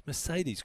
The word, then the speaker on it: Mercedes: But Mercedes, is it Mercedes? Yeah, it's Mercedes College, and that's how they pronounce it.